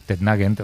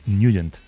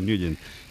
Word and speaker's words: Nugent: Hombre, siempre lo hemos llamado en el barrio Tednagent o Tednagent. – Claro, yo era de Tednagent. – Pero la pronunciación correcta es Nugent.